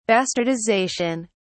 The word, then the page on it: bastardization: British English